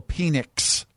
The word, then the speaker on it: Penix: Also got Michael Penix Jr. Michael what? – Penix. – Oh. – P-E-N-I-X.